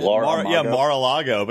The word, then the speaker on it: Mar-a-Lago: And I'm just going to be over here in Mar-a-Lago, or is that, how do you say it? – Mar-a-Lago.